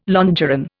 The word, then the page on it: longeron: Indian